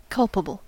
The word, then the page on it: culpable: [ˈkʌl.pə.bəl]; American English